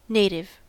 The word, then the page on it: native: American English